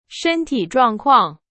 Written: 状况

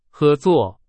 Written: 合

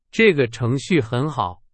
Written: 程序

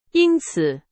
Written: 因此

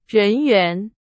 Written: 人员

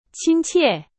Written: 亲切

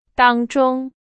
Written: 当中

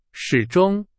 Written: 始终

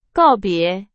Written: 告别

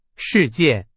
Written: 事件

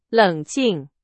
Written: 冷静

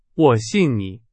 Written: 信

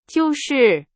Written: 就是

